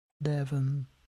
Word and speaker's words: Devin: Devin. – Okay. – You want me to spell it for you? – Is it D-E-V-I-N? – Yeah.